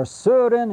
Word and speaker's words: Søren: All right, we want to begin with Søren Kierkegaard, or Søren, if you want to be very careful. His first name is spelled S-O-R-E-N, and you slash the O.